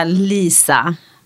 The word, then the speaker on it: Liza: Alltså jag försöker ju få dem att säga Lisa, men då stavar de det helt plötsligt med L-E-E-Z-A.